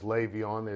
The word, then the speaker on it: Le'Veon: Yeah, and one of the things you learned about Le'Veon Bell was how to pronounce his first name correctly, which is Le'Veon. It is spelled L-E, but it's pronounced as if the E were an A.